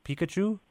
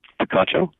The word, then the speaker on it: Picacho: Is it Pikachu? – Picacho.